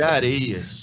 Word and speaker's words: Arêas: Falei certo, Fábio? Eu, tô, eu sou é, demais para errar tá, sobrenome. Tá, tá, vou te liberar dessa vez, mas só dessa vez é Areias.